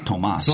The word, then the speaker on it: Tomas: That's actually Tomas. – Zelenka? – Because there's no H.